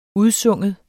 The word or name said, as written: udsynge